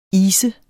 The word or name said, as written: ise